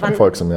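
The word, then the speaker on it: Van Volxem: äh, von Van Volksem, oder wie spricht man die? – Ja, Van, Van Volksem, ja.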